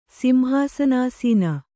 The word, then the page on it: ಸಿಂಹಾಸನಾಸೀನೆ: kannada